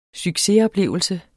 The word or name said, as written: succesoplevelse